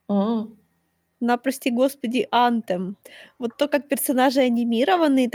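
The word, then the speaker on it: Anthem: Вот это настолько, как в демо, э, э как он правильно читается, антем, антем.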